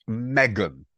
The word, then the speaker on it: M3GAN: Well, it is new to us, but it's been out now for over 10 days, which is pronounced Megan, but it's spelt M3GAN, all caps, which made 1.4 million.